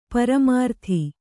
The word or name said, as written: ಪರಿಮೃತ